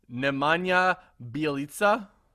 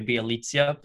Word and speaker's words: Nemanja Bjelica: And I had to get coached on this, so I'm really hoping I say this right. Nemanja Bjelica. – I believe that is correct. I might say Bjelica, but yeah. – Bjelica? Oh, man. – Either way, Bjelica I think is good.